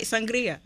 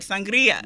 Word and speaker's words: sangria: Okay, you've heard me all day roll my R when I say white sangria. – We love it. – Okay, roll your R. Nice. Give it the respect that it's due.